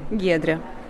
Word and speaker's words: Giedrė: Donc je vais dire Giedrė Bernotaitė Salfati. Donc ça, c'est la prononciation française. Maintenant, à une personne qui parle uniquement le lituanien, va-t-elle comprendre, quand j'aurai prononcé votre nom, que j'aurais dû prononcer ici ? – Bien sûr. – Autrement. – Giedrė.